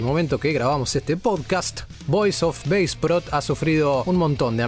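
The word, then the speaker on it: Voice of Baceprot: La banda de metal de Indonesia que se llama Voice of Bass Prot. Se escribe Voice of Base Prot.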